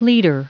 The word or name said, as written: liter